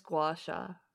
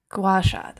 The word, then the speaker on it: Gua Sha: I think it's Gua Sha. – Gua Sha.